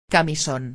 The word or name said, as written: camison